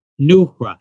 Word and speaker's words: Noohra: It's N as in Nick, O-O-H-R-A, Neura, and it means enlightenment.